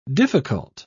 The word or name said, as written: difficult